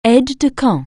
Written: aide-de-camp

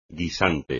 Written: guisante

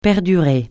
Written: perdurer

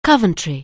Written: Coventry

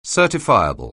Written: certifiable

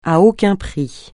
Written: à aucun prix

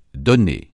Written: donner

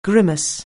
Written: grimace

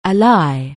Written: ally

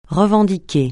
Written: revendiquer

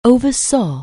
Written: oversee

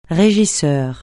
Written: régisseur

